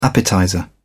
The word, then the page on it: appetizer: British English